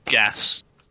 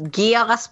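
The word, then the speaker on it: Geas: And we're talking about, you know, a game where one of the spells nowadays will be pronounced just as Gas, but I think is more commonly in D&D pronounced as Gaius. – It's pronounced Geass because of Code Geass.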